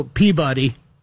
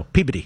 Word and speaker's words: Peabody: P-E-A, our friend Rufus, his last name. How do you say it? And there's a town with his last name. How do you say it? – Man, well, Peabody. – No, Peabody. – Of course. Yeah, yeah. – There's Worcester, and then there's G-L-O-U... C-E-S-T-E-R.